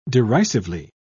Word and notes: derisively: British English

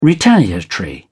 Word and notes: retaliatory: British English

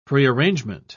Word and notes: prearrangement: British English

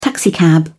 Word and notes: taxicab: British English

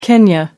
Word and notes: Kenya: American English

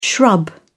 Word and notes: shrub: British English

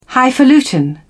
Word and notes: highfalutin: British English

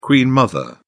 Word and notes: queen mother: British English

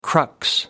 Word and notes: crux: American English

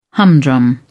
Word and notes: humdrum: British English

